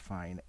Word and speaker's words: Fein: I believe it's pronounced Fine, F-E-I-N.